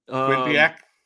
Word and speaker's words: Quinnipiac: You look at a team like Iowa who goes to, I think they're in Florida and they play like Quinnipiac, I think is how you say the name of the school. – Quinnipiac.